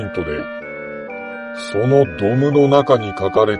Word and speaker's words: DOM: ドムというのは、